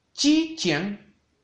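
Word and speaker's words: Chi chien (击剑): Hua Xue, Hua Esgrima en chino se dice Chi chien, Chi chien, Chi chien, con un primer tono en la I de Chi y un cuarto tono en la A de chiang.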